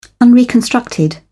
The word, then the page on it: unreconstructed: British English